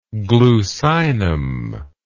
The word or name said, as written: glucinum